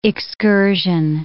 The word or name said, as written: excursion